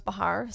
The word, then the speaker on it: Behar: I'm at Rose Bahar, spelled the same way that Joy Bahar spells her last name, BEHAR.